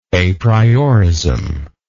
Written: aprioristically

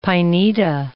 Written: pineta

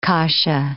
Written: kasha

